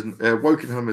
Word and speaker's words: Wokingham: You went to, Wokingham? Is that how you pronounce it? – Yeah. – Crackle and Wokingham? – Remember, college is, Wokingham is correct, yeah.